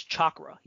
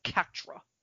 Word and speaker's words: chakra: Naruto has a little moment where he apparently doesn't even know how to pronounce chakra. He says katra.